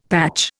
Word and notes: patch: Indian

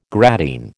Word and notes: gradine: American English

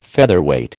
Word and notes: Featherweight: American English

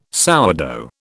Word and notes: sourdough: British English

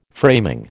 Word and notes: framing: American English

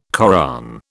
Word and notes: Koran: British English

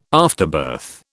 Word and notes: afterbirth: British English

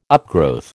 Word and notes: upgrowth: American English

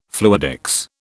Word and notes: fluidics: British English

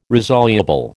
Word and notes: resoluble: American English

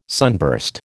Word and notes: sunburst: American English

